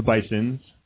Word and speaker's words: Bisons: It is the Bisons, and that's plural. – The Bisons? – Yeah. It's the school has decided to go with the plural pronunciation, and a lot of people like give us flack for that.